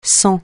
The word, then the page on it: sans: francais